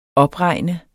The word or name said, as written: opregne